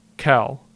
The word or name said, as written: Kell